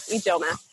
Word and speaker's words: Ijeoma: Yeah, Ijeoma, Nigerian.